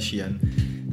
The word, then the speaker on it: Sheehan: E infatti per me, lo dirò adesso come dopo, il vero man of the match di questa finale è il signor Dan Sheena di Leicester. – Che Sheehan, ha fatto... – Comunque. Sheehan, vabbè, perché ho l'accento di, di Galway che a volte non si capisce, scusami.